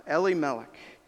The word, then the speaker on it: Elimelech: Gavin pronounced it different than I learned, so I'm trying to retell myself. Elimelech. Naomi and Elimelech face famine, and they leave the land.